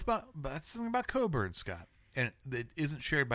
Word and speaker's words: Cockburn's: And it's spelled Cockburn's. – Cockburn. – But it's Coburn's.